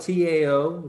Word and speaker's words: Tao: Yeah, it's spelled T A O. It's pronounced Tao in uh, Chinese dialect, one Chinese dialect, Mandarin.